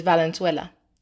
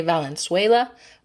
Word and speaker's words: Valenzuela: It is long, but it's pronounced a lot like it looks. Val, len, sue, la. Noting that the sue part is sue, not sway. Some people say Valenzuela, but that isn't quite right.